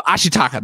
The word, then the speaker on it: Ashitaka: Ashitaka.